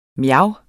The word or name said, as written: mjav